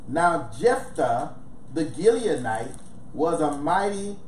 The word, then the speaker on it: Jephthah: Yes, Jephthah.